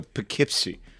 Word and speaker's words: Poughkeepsie: No, Poughkeepsie.